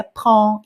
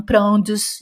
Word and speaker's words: prends: Se você conhece esse verbo, se escreveu com DS no final, mas você sabe que se pronuncia prends e não prendes.